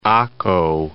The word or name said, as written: Accho